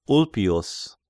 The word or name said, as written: Ulpius